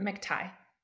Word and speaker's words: McTighe: Matigue, is it Matigue or Matigue? I'm not really sure. – It's ever. McTighe. – Matigue, thank you. Jay and McTighe.